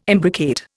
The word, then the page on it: imbricate: Indian